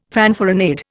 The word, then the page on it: fanfaronade: Indian